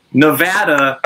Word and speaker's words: Nevada: Is that Americans will always correct me on like pasta, pasta, Mazda, Mazda, and then for some reason, Nevada is Nevada, not Nevada, which I think Nevada right. – is Canadian pronunciation of Nevada.